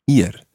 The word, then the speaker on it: ir: That verb is ir, ir.